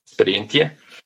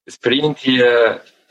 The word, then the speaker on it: Sperientia: Experiencia. Experiencia is my company.